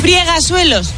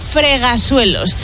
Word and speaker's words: fregasuelos: Friegasuelos, no se dice. – No. – Se dice fregasuelos.